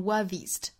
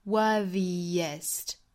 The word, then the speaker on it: worthiest: worthiest 大家不要直接读成 worthiest 应该是 worthiest 有一个小小的辅音来连贯两个元音